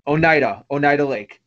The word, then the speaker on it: Oneida Lake: Oneida Lake? Oneida Lake.